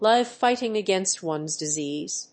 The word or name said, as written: live fighting against one's disease